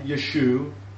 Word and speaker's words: Yeshua: But the Galilean name Yeshua stands, and you will find it in carvings in the first century. Just remember, the A was never pronounced in the Galil.